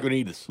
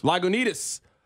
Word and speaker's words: Lagunitas: Lagunitas. – Lagunitas.